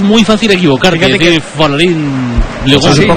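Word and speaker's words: Folarin Balogun: Dice Folarín Balogun. – Tiene que ser Folarín Balogun, vamos.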